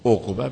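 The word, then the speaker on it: عقبة: خب از این جهت، خب ایشان نوشته در باب عقبه، در اون میگه عقبه به ضم العین و سکون القاف عقبه بگید. بعد گفتن یحتمل الفتحات الثلاث،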